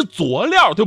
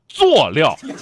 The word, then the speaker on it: 佐料: 很多朋友说是佐料，对不对？我到昨天为止也是这么以为的，但真正的读音是佐料。